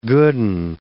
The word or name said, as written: good and